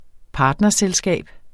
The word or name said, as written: partnerselskab